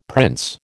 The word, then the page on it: prince: American English